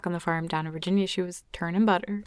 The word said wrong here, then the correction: churning: Is that how you say it? Turning? Churning? – Churning. – Churning. She was turning butter. – Churning with a CH.